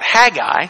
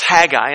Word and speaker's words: Haggai: So, because the right pronunciation is that Jewish guttural, okay? And I'm going to spare you, Haggai. And so I will spare you the mispronunciation and the butchering of the Hebrew language, okay? It's actually that hard CH, and it's actually not Haggai.